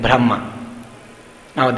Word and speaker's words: Brahman: And if you use the short A, it's actually Brahman.